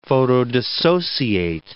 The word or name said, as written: photodissociate